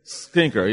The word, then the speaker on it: Skinker: how do you pronounce it, Skinker? Skinker, yeah.